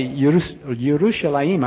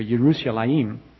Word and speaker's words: Yerushalayim: When we think of Jerusalem, the correct pronunciation is really Yerushalayim. I'm not sure I can do that in the morning. My tongue's not ready for Yerushalayim.